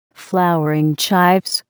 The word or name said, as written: flowering chives